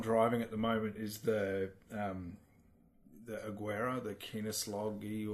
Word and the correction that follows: Koenigsegg: or whatever it is. – Koenigsegg.